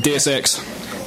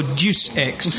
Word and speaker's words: Deus Ex: Deus Ex. – No, Deuce Ex. – Look it up. You're still saying it wrong. – Absolutely. – If I look it up, it's going to be spelled. And I'm still going to pronounce it that way.